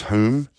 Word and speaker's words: Home: Mr. Speakeasy might like to tell us how they get to pronounce home as whom, as in Alexander Frederick Douglas Hume.